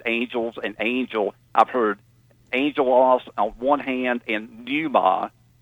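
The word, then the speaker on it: angelos (ἄγγελος): Angelos, it's A-N-G-E-L-O-S is the way we'd spell it. It's in Greek, it's gamma, gamma, so alpha, gamma, gamma, but the G-G becomes N-G. So angelos, and that's where we get angel from, is the word.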